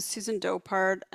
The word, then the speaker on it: Susan Dopart: I'm Susan Dopart.